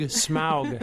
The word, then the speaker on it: Smaug: Smaug. – Smaug? – Not like the pollution. It's like Smaug.